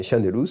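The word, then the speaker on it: Chanelou (シャネル): D'abord, étrangement, Chanel, c'est facile à prononcer en japonais. Chanelou, c'est très facile à dire en japonais.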